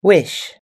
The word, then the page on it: wish: American English